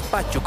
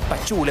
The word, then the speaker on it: Pachú: por si acaso se pronuncia, no es Pachu, como muchos le dicen, lo asocian a Pachi, tal vez es Pachú. Le preguntamos en Achumani y él dijo que la entonación va en la U, en ese sentido. De esa manera forma Die Strongest con el 3-4-3 para este partido.